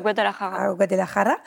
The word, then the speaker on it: Guadalajara: C'était où à Mexico euh, à Guadalajara.